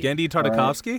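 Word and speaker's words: Genndy Tartakovsky: And I have the entire Gendry, Tavarsky, however you say his name, and did Samurai Jack. I have his whole Clone Wars on DVD. – Gendry Tartakovsky? – Right? Yeah, that's how you say it.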